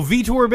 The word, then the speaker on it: Vitor: So here is the clip of Victor Belfort saying, let's make... – Oh, Vitor Belfort.